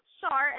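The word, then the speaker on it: Schär: Yeah, so it's pronounced Shar, and it's spelled S-C-H-A-R.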